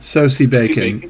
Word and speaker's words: Sosie Bacon: And it stars, is her name like Sosie Bacon? Is that how you pronounce it? – Sosie Bacon. Yep.